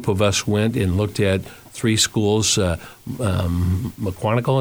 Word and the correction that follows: McGonigal: is that Am I pronouncing it right? – McGonigal. Yeah.